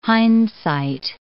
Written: hindsight

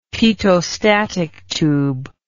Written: pitot static tube

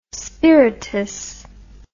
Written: spiritus

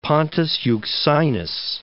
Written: Pontus Euxinus

